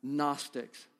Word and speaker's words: Gnostics: Starts with a G, G is silent. Gnostics, Gnosticism.